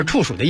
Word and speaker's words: 处暑: 哎呦，我打小一直管这个字念处，然后我那会儿还自己瞎解释，我说处暑的意思大概就是处处都中暑。